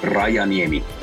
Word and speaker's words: Rajaniemi: Rajaniemi.